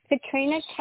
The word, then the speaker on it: Katrina: Katrina, K-A-T-R-I-N-A. – Yep, last name? – Buchholz, B-U-C-H-H-O-L-Z.